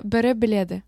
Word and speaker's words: Bari Bilet: Can you pronounce that again? – Yeah, Bari Bilet.